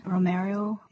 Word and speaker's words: Romero: Could you just briefly let listeners know who Archbishop Oscar Romero is? – Well, Archbishop Romero, you said that right.